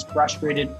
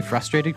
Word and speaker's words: frustrated: I hate when people pronounce frustrated. They lose the R. – Really? Do people do that? – All the time. – Wow. I'm shocked at that. People don't say frustrated correctly?